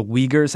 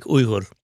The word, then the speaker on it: Uyghur: You focus on Islam in China and on the Uyghurs. Am I saying that right? Uyghurs? – Yeah, Uyghur. – But you can also say... – The Uyghur pronunciation is something more like Uighur.